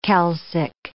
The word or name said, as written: calcic